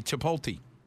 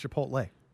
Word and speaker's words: Chipotle: Chipotle. – You said it wrong. It's Chipotle.